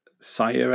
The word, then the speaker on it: Cire: Yeah, he's Administrator Sire, I guess you pronounce it, C-I-R-E.